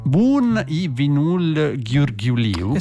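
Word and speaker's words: Bun e vinul ghiurghiuliu: Il titolo è Bun i Vinul Ghiurghiuliu. – Esattamente così, proprio la pronuncia è questa.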